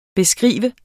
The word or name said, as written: beskrive